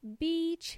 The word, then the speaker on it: beach: So what does beach mean? To go to the beach. Ir a la playa. Beach. In this case, is pronounced with a long E. Beach. B-E-A-C-H.